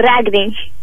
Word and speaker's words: Ragni: Hallo, wat is je naam precies? – Ragni. – Oh, Ragni. Sorry, dan zeg ik het helemaal verkeerd.